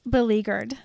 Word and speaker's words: beleaguered: It's a terrible looking word. It's a word that I don't ever want to look at. – I really hate looking at it. – But it is pronounced beleaguered.